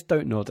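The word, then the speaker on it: Dontnod: Have they confirmed that this is definitely the Life is Strange game that it is Don't Nod? That's how you pronounce it, right? – Right, yeah.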